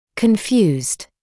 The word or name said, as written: confused